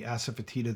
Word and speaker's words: asafoetida: How much of that differentiation comes from the asafoetida that you include in the flavor profile of the chips? And did I pronounce that properly? – Absolutely.